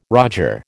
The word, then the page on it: roger: American English